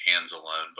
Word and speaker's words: Anzalone: One is Florida's linebacker, Alex Anzalone. I hope I'm saying that right. Anzalone. I don't know much about him. Do you have any idea on him? – My Italian says it's Anzalone, but I know he was a pretty good player.